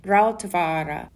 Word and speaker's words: Rautavaara: It's Einojuhani, which is his first name, and the final his his last name is Rautavaara.